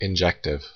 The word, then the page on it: injective: American English